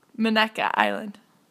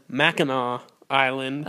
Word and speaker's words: Mackinac: Manaka Island. – We're going to go with Manaka Island. Also known as Mackinac Island in Michigan.